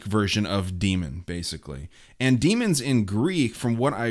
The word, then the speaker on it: daemons: Demons spelled D-A-E-M-O-N-S, pronounced demons.